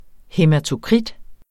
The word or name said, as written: hæmatokrit